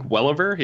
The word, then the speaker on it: Welliver: Is that how you pronounce it? – I think Welliver, yeah.